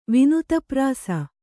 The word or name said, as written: ವಿನುತಪ್ರಾಸ